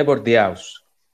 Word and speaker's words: Bordeaux: Bordeaux 4.